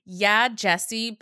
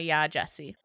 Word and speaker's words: Yaa Gyasi: So my plug of the week is going to be a book called Homecoming, and it's by Yaa Gyasi, but it's spelled Y A A G Y A S H I.